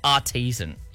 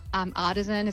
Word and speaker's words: artisan: So what makes a pizza artesian? Is I'm saying that right? – yes. Artesian. Artisan is another way it's said.